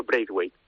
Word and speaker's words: Braithwaite: A Braithwaite. ¿Lo he dicho bien? – Sí. – ¿Braithwaite? – Sí, yo pronuncio Braithwaite, pero vamos.